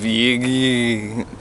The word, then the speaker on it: Yegge: I read a great article by, I don't know how to pronounce his name, Steve Yeeg. Y-E-G. – No, that's it. No, you got it. – Y-E-G-G-E.